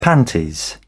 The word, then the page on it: panties: British English